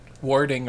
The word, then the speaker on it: warding: Warding, like it's not like wording, it's like warding, warding. – It seems to Ward. – me that you say it like the same. – Yeah, but I'm saying WO and WA.